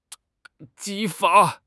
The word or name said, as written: 激发